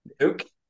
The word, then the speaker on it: Nuuk: like so many people in how would you pronounce it? Nuke. – Nuke. Nuke. Yeah, the capital of Greenland. – N U U K.